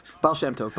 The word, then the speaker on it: Baal Shem Tov: Baal Shem Tov.